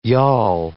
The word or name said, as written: yawl